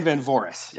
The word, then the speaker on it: Van Voorhis: Dan Van Voorhis, yeah.